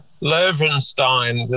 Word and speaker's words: Löwenstein: Well, it's actually it's Löwenstein. It's the W is pronounced as a V, but um, yes.